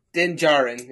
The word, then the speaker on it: Din Djarin: Like Din and then Jarro. – Din Djarin is how it's pronounced.